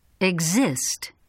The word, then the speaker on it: Exist: The letter X is sometimes pronounced as g plus z. Repeat these examples. – Examine. Exact. Exaggerate. Exist.